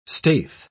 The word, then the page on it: staith: British English